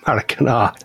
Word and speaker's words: Maracanã: And the other one is Rio de Janeiro's Maracanã. – Maracanã, I pronounced that right. – Maracanã.